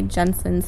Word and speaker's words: Jensen: Danny is spelled D-A-N-I, Jensen spelled J-E-N-S-E-N,